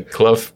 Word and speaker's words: Clough: is it Clough? C-L-O-U-G-H? – Clough. – Clough. – Clough. – Yeah.